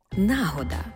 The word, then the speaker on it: нагода: До слова, Борис Грінченко на сторінці 477 другого тому свого словника подає іменник «нагода» і чомусь з наголосом на першому складі, як відповідник російських слів «случай», «удобнеє время».